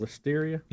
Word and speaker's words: Listeria: Is it Listeria? Is that how you say it? – Listeria.